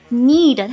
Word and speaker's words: knead: 注意一下这个 knead， K-N-E-A-D， need，它的读音跟需要那个单词是一样的。